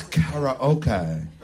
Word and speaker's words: karaoke: It's karaoke. That's how you say it. It's not racist because that's how you say it. I said it correctly. You guys are saying it wrong.